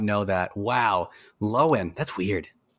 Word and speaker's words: Lohan: Lindsay Lo Lindsay Lohan is actually Lindsay Lowen. Lowen? – The H is, is silent, yeah.